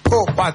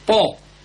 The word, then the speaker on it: Paw: Se escribe P-A-W. – ¿Sí? – Po. – Po.